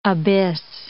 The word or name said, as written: abyss